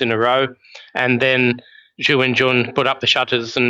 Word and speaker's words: Zhu Wenjun: Zhu Wenjun defeated Tan Zongyi by the narrowest possible margin, if it didn't go to tiebreaks.